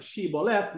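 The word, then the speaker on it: Shibboleth: The Ephraimites were unable to pronounce the aspirate, as indeed the Greeks also have no sh sound, and said Siboleth. So for them, it was a dead giveaway. Siboleth, without the H sound, the sh, means burden.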